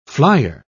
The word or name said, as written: flyer